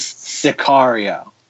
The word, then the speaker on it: Sicario: It is Sicario.